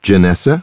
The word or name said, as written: Janessa